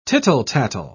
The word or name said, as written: tittle-tattle